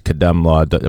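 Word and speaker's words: KhademLaw: And before I forget, if you want to get in touch with her, it's Kadamlaw.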